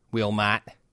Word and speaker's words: Wilmot: Wilmot. That's how you say it in Chicago.